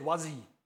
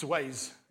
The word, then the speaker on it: Waze: Or like my old friend said, Wazzy. It's Waze, yeah. He pronounced it Wazzy and I have no clue what he's talking about. I'm like, Wazzy? What is that? It's Waze.